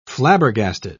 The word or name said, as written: flabbergasted